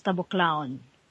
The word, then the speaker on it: Taboclaon: My last name is Taboclaon.